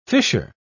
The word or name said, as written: fisher